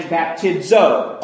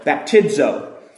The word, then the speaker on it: baptizo: And we get the word baptisma and baptizo, a long O. The omega at the end becomes a long O, baptizo.